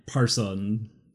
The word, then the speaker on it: Persson: And then during the making of their first demo tape, we would pronounce it person. I tried to find a actual pronunciation. – Let me see the... – In P-E-R-S-S-O-N. – Person? – Yeah, I heard, like, Parson.